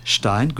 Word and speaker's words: Stein: Stein.